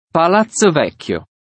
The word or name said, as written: Palazzo Vecchio